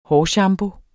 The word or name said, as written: hårshampoo